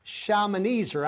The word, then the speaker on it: Shalmaneser: And Shalman, that's short for Shalmazar, Shalmaneser, I'm sorry, the king of Assyria. I don't know why I say that wrong every time.